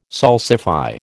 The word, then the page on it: salsify: American English